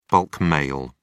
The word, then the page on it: bulk mail: British English